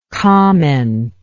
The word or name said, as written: commenter